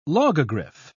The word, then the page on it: logogriph: British English